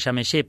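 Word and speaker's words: Przemyśl: O sigui, vaig començar a l'estació de Xemeixé, es pronuncia Xemeixé, però és Percemisil, un nom molt estrany,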